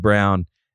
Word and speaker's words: Braun: Spelled like Braun, pronounced like Brown.